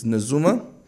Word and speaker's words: Najuma: You said your daughter is Najuma. Let me make sure I have all the pronunciation. – N-A-J-U-M-A, Najuma. – Najuma. Right. Good morning and welcome, Najuma.